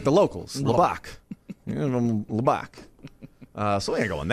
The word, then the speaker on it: Lubbock: You know, if North Carolina fires, if Texas Tech moves on, like he's not going to go live in Lubbock. Like that ain't happening. – Lubbock.